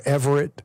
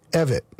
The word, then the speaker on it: Evett: Nancy Mace was calling her Everett, but it's Evett. Like, the way I remembered it when I first met her was, it's like Effett, but Evett. That's how I remembered how to pronounce her name.